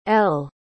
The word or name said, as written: elle